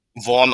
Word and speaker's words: Vaughn: da ist jetzt, muss man schauen, ist wahrscheinlich das gleiche wie mit dem Quarterback, der Wide Receiver Chris Vaughn oder Vaughn. Ich glaube, Chris Vaughn würde ich schätzen. – Vaughn ausgesprochen, Chris Vaughn.